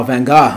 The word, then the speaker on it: Van Gogh: Oh, is that how the betters say it? – Yeah, that's how it's actually pronounced, Van Gogh. – Oh, Van Gogh.